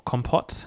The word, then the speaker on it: compote: One of the toppings we've got on our vanilla ice cream is a mixed berry compo. Or compote, as some people like to pronounce it.